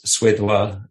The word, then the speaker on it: Suédois: Desert Blues, Suédois.